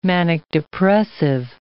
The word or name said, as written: manic-depressive